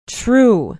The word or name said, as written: true